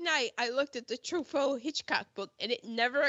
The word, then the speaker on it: Truffaut: Francois Truffaut. Did I say that right? – Truffaut.